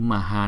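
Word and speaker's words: Mahā: Cái chữ Maha này chúng ta đọc là Maha, bởi vì chữ A này có dấu gạch ngang trên đầu, chúng ta sẽ đọc chậm hơn là chữ A đằng trước.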